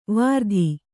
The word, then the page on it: ವೃತ್ತಿ: Kannada